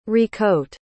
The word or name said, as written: recoat